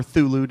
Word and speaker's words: Cthulhu: Thursday, August 14th, 2014, Chris LaMartinez's newest film, Call Girl of Cthulhu, or Thulu, depending on your how you want to pronounce it,